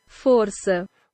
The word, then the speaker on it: Força: The name of the band translates into English as strength, but pronounced in Portuguese, according to Google Translate, Força.